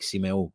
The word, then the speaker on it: Symeou: Georgios correcting me on how to pronounce my name. I know that's how it's pronounced and that's how I say it in Greek, Simeo, but this is the anglicized version, right? You've